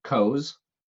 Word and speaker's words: Coeus: My other favorite is Coes. Just straight Coes. And I don't think any of these are technically pronounced wrong, because I'm pretty sure that even in Greek language, it's not Coeus. That's probably a fairly Americanized version of saying the name.